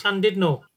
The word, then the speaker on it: Llandudno: And you know, they pronounce the U as an I, so it's Llandudno.